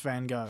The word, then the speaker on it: Van Gogh: It's Van Gogh. It's pronounced Van Gogh.